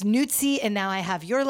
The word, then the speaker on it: Nuzzi: and you pronounced her name Nuzzi, and I'm like, thank God,